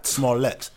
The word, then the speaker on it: Smollett: Smollett.